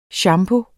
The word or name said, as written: shampoo